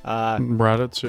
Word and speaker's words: Raditz: Raditz, yeah.